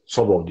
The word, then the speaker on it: Sobol: Sobol diye okunuyordu.